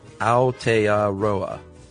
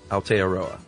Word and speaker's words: Aotearoa: Aotearoa, or land of the long white cloud, is pronounced Aotearoa. Aotearoa.